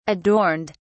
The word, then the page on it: adorned: British English